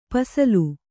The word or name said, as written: ಪಸಲು